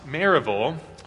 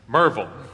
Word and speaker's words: Maryville: I'm from a little town in Tennessee, spelled Maryville, but pronounced Maryville, or if you're really an old-timer, it's Merville.